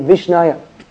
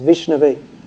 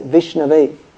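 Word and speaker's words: Vishnave (विष्णवे): So it would seem that if you're going to offer something to, you're going to say to Vishnu, you would say Vishnaya. But actually this is an irregular case, and the proper way to say is Vishnave. So a half-learned person, a fool, murkam, he may say Vishnaya. But an actually learned person, someone who's studied, he says Vishnave.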